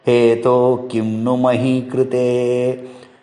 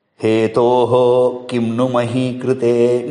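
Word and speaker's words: hetoḥ: That visarga you should not pronounce. What I mean to say is that hetoho kimnu mahi krute need not be pronounced.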